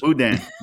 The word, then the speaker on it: boudin: And let's see, your wife, I was in the South this week, and I ate some, did I say this right? Boudin? Is there an N on the end? – Boudin. – Okay.